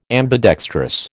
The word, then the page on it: ambidextrous: American English